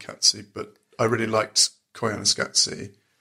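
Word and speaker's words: Koyaanisqatsi: I'm just really impressed with your pronunciation of it, because I didn't know that's how it was said. – Well, it's in the soundtrack, they go, Koyana Skatsi.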